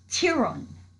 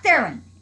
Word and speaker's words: Theron: Theron. It's not Theron. It's not Theron. That is not how you pronounce her name.